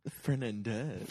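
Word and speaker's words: Fernández: He said, OKC is looking into acquiring Rudy Fernandez. Thoughts on how he could contribute? Does interest guarantee Thabo's departure? – Fernandez.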